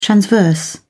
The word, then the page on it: transverse: British English